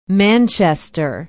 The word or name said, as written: manchester